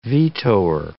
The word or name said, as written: vetoer